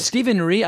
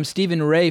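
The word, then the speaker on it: Stephen Rea: Stephen Ree? I've been calling him Stephen Ray for decades. Stephen Ree's the correct pronunciation.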